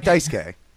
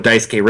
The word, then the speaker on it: Daisuke: It's like Daisuke. – I always thought that was just like saying Daisuke because no one could actually say what Daisuke really was. – No, that's how it's said.